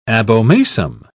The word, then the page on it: abomasum: British English